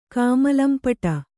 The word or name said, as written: ಕಾಮಲಂಪಟೆ